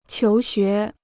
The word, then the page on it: 求学: qiú xué